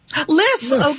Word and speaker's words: Lyf: Is it Leif or Life? – Lyf, okay, there we go. L-Y-F.